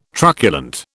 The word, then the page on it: truculent: British English